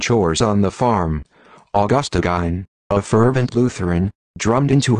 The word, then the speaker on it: Gein: Alex, the Mac voice is going to mess up his name. It's pronounced Gein.